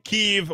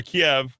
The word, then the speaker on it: Kyiv: Yet he says, your hero Zelensky says, the only way you'll take Kyiv or Kiev, whatever you're pronouncing, pre-2014 or post-2014. That's when it all changed somehow. Okay, Kyiv, Kiev.